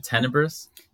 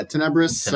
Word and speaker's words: Tenebris: So, tell me again, Tenebris. – Yeah, Tenebris. – Tenebris. – Yeah, it's a Latin word. So I guess the correct pronunciation would be like Tenebri.